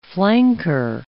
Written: flanker

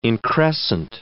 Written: increscent

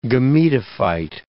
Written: gametophyte

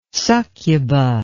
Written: succuba

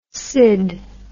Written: syd

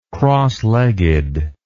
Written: crosslegged

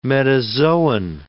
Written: metazoan

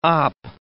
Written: op

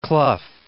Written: Clough

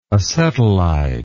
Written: acetylide

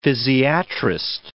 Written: physiatrist

